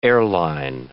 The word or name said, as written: airline